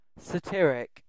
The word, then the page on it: satiric: British English